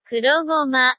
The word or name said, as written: kurogoma